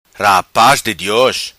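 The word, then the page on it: Rapaz Di Dios: patua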